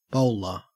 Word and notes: bowler: Australian English

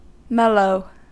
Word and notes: mellow: American English

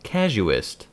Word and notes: casuist: American English

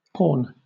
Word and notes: porn: British English

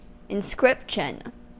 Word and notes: inscription: American English

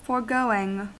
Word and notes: foregoing: American English

